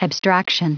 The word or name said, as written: abstraction